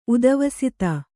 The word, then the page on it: ಉಧ್ವಸ್ತ: udhvasta